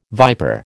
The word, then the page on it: viper: American English